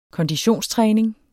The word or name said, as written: konditionstræning